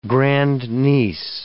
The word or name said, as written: grandniece